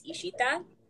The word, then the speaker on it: Ishita: The Bengali pronunciation is like you draw out your letters, so it's Ishita.